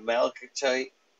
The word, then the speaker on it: malachite: I used to carry uh, a malachite.